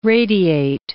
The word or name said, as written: radiate